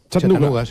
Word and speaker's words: Chattanooga: ¿Se pronuncia así en Chattanooga? – Chattanooga, sí.